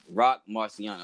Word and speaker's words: Rock Marciano: Uh, Rock Marciano.